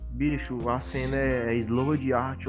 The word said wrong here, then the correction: Sword: É Sword Art Online.